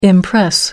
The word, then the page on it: impress: American English